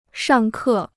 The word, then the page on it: 上课: shàng kè